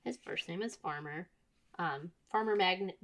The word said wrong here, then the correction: Maggot: Jesus. How many times can I mispronounce Farmer Maggot's name? – I love it. – Farmer Maggot and Gildor the Elf.